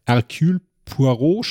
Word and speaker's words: Hercule Poirot: Ermittler Hercule Poirot spielt.